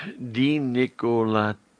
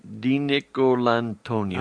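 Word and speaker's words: DiNicolantonio: Well, it's D'Nicolantonio. D'Nicolantonio. – All right. – It's like the last part is Nicole or D in the first part.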